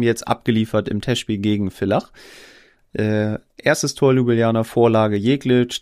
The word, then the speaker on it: Villach: Entschuldigung, wir haben es letztes Mal falsch ausgesprochen, wir haben Ärger bekommen, ne? Villach. Weil ja V, kein W.